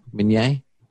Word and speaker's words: Minier: So it's a French last name, actually. In French, it's pronounced Minier. – Can I say it like that? Minier? – Sure. – I like that. But it's been Americanized to Minier. But yeah, like three generations ago, it was Minier.